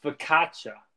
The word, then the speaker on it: focaccia: It, it's it's pronounced focaccia. Focaccia.